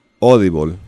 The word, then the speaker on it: Audible: Hay un sitio muy grande, se llama Audible, se dice en inglés, se escribe Audible, que lo compró Amazon Audible hace unos años y se especializa en audiolibros.